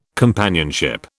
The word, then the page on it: companionship: British English